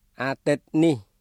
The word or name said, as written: អាទិត្យនេះ